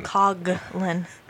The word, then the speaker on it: Coughlan: Oh, you got the last name wrong. – Coughlan. – No, it's I think you have it wrong.